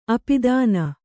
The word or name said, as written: ಅಪದಾನ